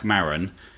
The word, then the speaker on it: Maron: And we're going to stick with that pronunciation, aren't we? Maron. – I don't intend to say his name at all. – Okay. From now on, we might call him Mark or double M. – Yeah. – If we're pronouncing it wrong, please don't let us know. – I don't think we are. I think that's fine.